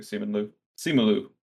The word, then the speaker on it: Simu Liu: I know Simon Liu, I hope I'm pronouncing that correctly. Simu Liu.